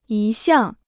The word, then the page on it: 一向: yī xiàng